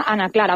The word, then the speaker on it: Ana y Clara: siempre hago mucho hincapié en que aunque se escriba Ana y Clara, con esta I inglesa, se pronuncia Ana Clara, o sea, sé todo seguido. Ana Clara, Ana Clara Spritz.